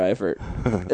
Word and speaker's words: Eifert: I hope that, how do you pronounce his name? – Eifert. Tyler Eifert.